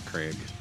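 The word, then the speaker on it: craic: I think it's Craig. – Craig. – Craig. You gotta sound like you're throwing up. Craig. – I think that's a C. C R A I C.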